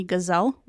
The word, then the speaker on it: Ghazel: And today we have Anthony Ghazel with us. Did I say your last name right, by the way? – That is correct. Ghazel, like the animal.